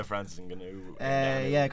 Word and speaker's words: Francis Ngannou: But the main fight on the undercard, your boy Francis Ngannou, am I saying that correctly? – Francis Ngannou.